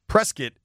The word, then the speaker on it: Prescott: Prescott is how they pronounce it.